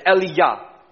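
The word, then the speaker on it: Eliyahu: Do you guys know how you say Elijah in Hebrew? Eliyah. Eliyah.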